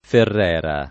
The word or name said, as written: Ferrera